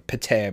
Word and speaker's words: Pétain: Pétain, I believe, is the way to pronounce his name.